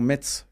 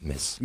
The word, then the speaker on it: Metz: Effectivement, depuis quelques semaines, tous les commentaires, on a enfin compris que Metz se prononce Metz.